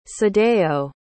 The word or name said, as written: sadayo